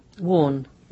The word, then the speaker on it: Warne: Warne.